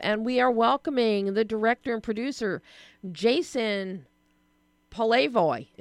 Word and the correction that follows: Polevoy: Did I say it right, Jason? – You were close. It's Polevoy. – Polevoy. Okay. I just had the accent on the wrong syllable.